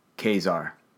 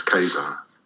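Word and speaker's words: Ka-Zar: But Both are how do you pronounce K A dash Z A R? – Kazar. Yeah. I say Kazar. Yeah, it's Kazar.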